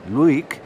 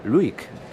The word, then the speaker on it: Lewek: Vamos a hablar en primer lugar de la soprano norteamericana, la soprano estadounidense Catherine Luick, que se escribe su apellido Lewek, L-E-W-E-K, pero se pronuncia Luick, Catherine Luick.